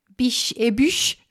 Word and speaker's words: Biches et Bûches: Biche et bûche, ja. Franskt. – Ja. – Stavas biches et bûches, men så uttalas det inte.